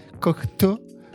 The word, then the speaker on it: Cocteau: como eu aprendi a pronúncia correta, Cocteau, Cocteau Twins. É... – eu fiquei pensando nisso porque eu pensei, é muito comum na minha cabeça falar Jean Cocteau.